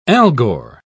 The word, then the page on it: algor: British English